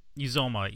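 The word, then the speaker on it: Uzomah: Am I saying that right, Cincinnati man? Is it Uzoma? – Uzoma, yes.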